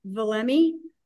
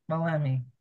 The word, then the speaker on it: Valemee: The way we pronounce our name is Valemee. – Valemee.